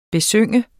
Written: besynge

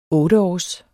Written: otteårs

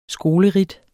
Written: skoleridt

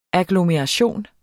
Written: agglomeration

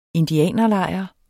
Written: INDIANERLEJR

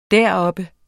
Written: deroppe

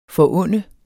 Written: forunde

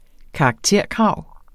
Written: karakterkrav

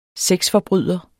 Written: sexforbryder

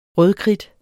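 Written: rødkridt

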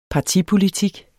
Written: partipolitik

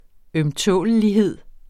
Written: ømtålelighed

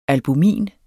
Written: albumin

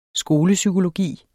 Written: skolepsykologi

